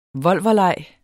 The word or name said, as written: volverlej